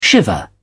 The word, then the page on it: shiver: British English